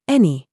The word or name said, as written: NOUN